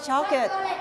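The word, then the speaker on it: chocolate: แต่ภาษาอังกฤษเรออกเสียงว่าช็อกเกตช็อกเกต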